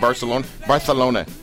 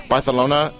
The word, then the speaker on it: Barcelona: Over his long career in sports broadcasting, Lance has covered the Olympic Games, specifically Calgary, 1988, Barcelona, you have to say it like that. – Yes, you do. – Barcelona, 1992, and Lillehammer.